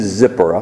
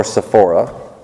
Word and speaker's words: Zipporah: Her name is Zipporah, or Sephora, depending on how you pronounce it.